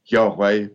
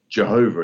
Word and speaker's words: Yahweh: So what we're left with in Hebrew scripture then is what's known as the Tetragrammaton, literally meaning the four letters, YHWH, is what is pronounced as Yahweh, or sometimes rendered as Jehovah.